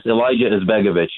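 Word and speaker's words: Alija Izetbegović: Is it Alijah Azerbegovic? I don't know how to say. – Huge fan. We're huge fans. Yeah, huge fan. Do you know the name? By the way, yeah. I butchered it. – Yes, Elijah Izbegovic.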